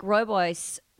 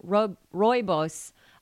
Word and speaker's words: rooibos: Which is interesting, because most people who drink rooibos, I'm saying that wrong, rooibos. – There you go. That's it.